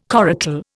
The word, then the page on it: coracle: Indian